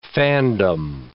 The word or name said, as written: fandom